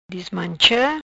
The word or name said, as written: Dismancha